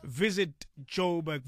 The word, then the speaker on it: visit.joburg: For more ideas on exploring Joburg, see our website, visit Joburg, visit.joburg. That's actually how you say it.